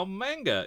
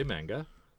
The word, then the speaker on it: manga: During episode 502, you ask about how manga is produced. – Here oh, we go again. – The ma sound, sound is pronounced like the ma in mama, not the ma in man.